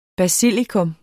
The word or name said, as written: basilikum